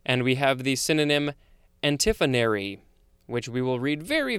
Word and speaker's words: antiphonary: And now we have antiphonary. That's how it's pronounced. A-N-T-I-P-H-O-N-A-R-Y.